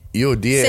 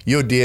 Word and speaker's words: Euodia: How do you say that, man? – Let me see. – Let's see. Euodia. – Syntyche. – Syntyche. Okay. Euodia, and I implore you, Syntyche, to be of the same mind in the Lord.